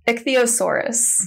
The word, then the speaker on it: Ichthyosaurus: So it's Ichthyosaurus.